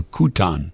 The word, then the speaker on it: Kuchen: Somebody answered the question of what kuchen is, K-U-C-H-E-N. It's a coffee cake. Kuchen, apparently, is how it's pronounced.